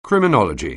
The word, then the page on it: criminology: anglais